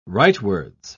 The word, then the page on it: rightwards: British English